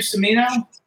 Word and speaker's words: Cimino: So let's just, you have a cousin, Michael Cimino. Is that how you pronounce his name? – Cimino.